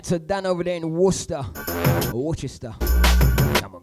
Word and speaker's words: Worcester: This one out to Dan over there in Worcester, Worcester. Get the right pronunciation on that one.